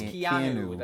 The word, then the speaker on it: Keanu: Keanu. – It's Keanu.